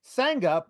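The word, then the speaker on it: Sanga: Mark Losper says, fun fact, Sangha, pronounced like it rhymes with banger.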